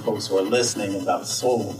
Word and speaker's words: Sol: So soul, when you hear it, the first thing that you think of is your soul, your hearts and your minds. But when you see it, it's spelled S-O-L and it's the power of the sun.